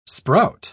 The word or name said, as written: sprout